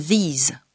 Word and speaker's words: these: these » au pluriel, qui se prononce avec un « i » long et un son final « z ».